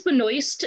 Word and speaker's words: Benoist: I believe it's Benoist, and then she was saying like in the French it was probably Benoit, and then they're like, maybe we should say Benoit.